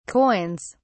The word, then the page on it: coins: British English